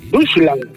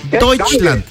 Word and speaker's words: Deutschland: Vaya, se dice Deutschland. A ver, repeat. Deutschland.